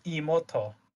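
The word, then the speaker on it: imouto: So, you are Fan-kun, and you are woken up for breakfast by your little sister, Imotu-chan. Today's breakfast is an omelet made from Imotu's... Is that how you pronounce that enigma? – It's Imoto. – Imoto.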